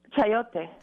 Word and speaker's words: chayote: And as many Americans know, how do you say that? Chayote? – Chayote.